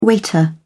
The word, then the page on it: waiter: British English